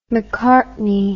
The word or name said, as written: mccartney